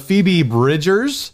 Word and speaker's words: Phoebe Bridgers: Phoebe Bridgers.